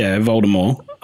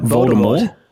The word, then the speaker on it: Voldemort: It's a silent T. – Voldemort. – But there is a T. – Yeah. There is a T, but it's silent.